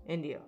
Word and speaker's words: Indio: To the UFO researcher, this case is known as the crash at El Indigo. No. – Indio.